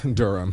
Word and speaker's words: Durham: Of North Carolina, Durham. Durham. Glenn, I just got so excited that I pronounced it right.